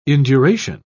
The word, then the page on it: induration: British English